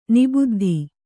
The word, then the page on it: ನಿಬುದ್ದಿ: kannada